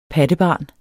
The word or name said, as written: pattebarn